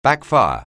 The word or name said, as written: backfire